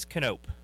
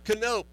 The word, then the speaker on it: Knopp: In the studio with me right now is Joshua Knopp. Am I saying that right? – It's Canope. – Canope.